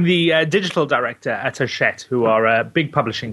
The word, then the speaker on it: Hachette: Now, Hachette, that sounds remarkably like Battle Axe. Hachette, French company. – There, you know, there's an entire British sitcom in there. It's no, it's pronounced Hachette.